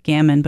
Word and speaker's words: gamine: G-A-M-I-N-E. Some people say gammon, but I say gamine.